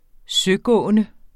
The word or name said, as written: søgående